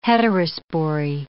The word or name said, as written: heterospory